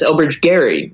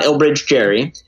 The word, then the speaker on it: Elbridge Gerry: It's Elbridge Gerry, not Elbridge Jerry. And the G, the hard G, got softened over time.